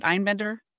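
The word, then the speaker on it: Einbinder: Hannah Ein, is it Einbender? – Yes.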